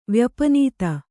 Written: ವ್ಯಪನೀತ

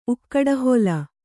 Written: ಉಕ್ಕಡಹೊಲ

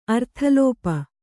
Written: ಅರ್ಥಲೋಪ

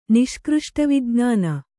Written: ನಿಷ್ಕøಷ್ಟವಿಜ್ಞಾನ

